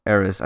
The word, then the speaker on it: Eris: The Harris. – Eris. I think it's Eris. – Okay. The H is silent.